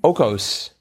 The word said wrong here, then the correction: ocho: Ocho. – Ocho, yeah, exactly. – Cuatro plus cuatro. – Yep. – Ocho. – I now know how to say the number 8 in Spanish.